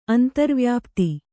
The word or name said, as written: ಅಂತರ್ವ್ಯಾಪ್ತಿ